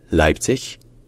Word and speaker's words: Leipzig: Avec dans les deux cas, bien sûr, l'accent tonique qui est sur la première syllabe. Leipzig, bien Leipzig. Mais jamais avec un g à la fin, parce qu'en allemand, le g final se devient toujours un que.